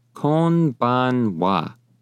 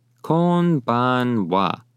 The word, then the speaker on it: konbanwa: You'd say konbanwa. Konbanwa. That is how you say good evening in Japanese.